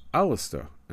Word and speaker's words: Aleister: Their claims amuse Scots occultists, since we know that the name Alexander, which Crowley was blessed with, is pronounced Aleister in Scottish Gaelic.